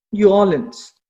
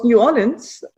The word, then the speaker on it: New Orleans: Wilbert stammt ursprünglich aus New Orleans. Ich glaube, ich muss das anders aussprechen. Wie heißt das? – Nee, das war richtig.